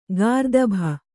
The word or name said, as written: ಗರ್ದಭೆ